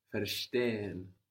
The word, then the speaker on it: verstehen: The German V sounds like F. Verstehen means to understand. This is spelled V-E-R-S-T-E-H-E-N. – Verstehen. – Verstehen. – So again, we see the V sounding like an F, V-E-R, and then we get Stehen. – S-T-E-H-E-N.